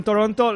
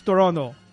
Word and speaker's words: Toronto: Ottawa, Esto, pronunciación canadiense, igual que Toronto. Los americanos dicen Toronto, los eh, de Toronto nativos es Toronto.